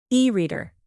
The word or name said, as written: e-reader